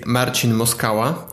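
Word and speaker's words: Marcin Moskała: In Polish it would be Marcin Moskala. But I think this is one of the best pronunciation I got from from Western words.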